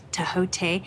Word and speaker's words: Thoth: Hermes is one of the ancient, that's the Greek name for him, but there's also an Egyptian name for him, which is Thoth, T-H-O-T-H, actually it's pronounced Tehote.